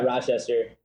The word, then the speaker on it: Rochester: And then, I just want to wish you congratulations to you and your girl, for starting your life together in Rochester. Yep. Is that how you pronounce it? – Yeah, Rochester.